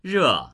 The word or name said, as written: re4